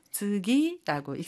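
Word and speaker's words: 즈기 (次): 버금차라고 하는 한자를 사용해서 즈기라고 읽습니다.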